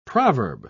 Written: proverb

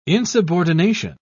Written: insubordination

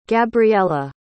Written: gabriela

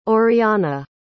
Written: auriana

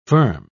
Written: firm

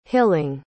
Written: hilling

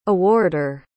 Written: awarder

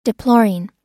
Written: deploring

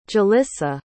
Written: jolissa

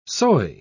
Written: soy

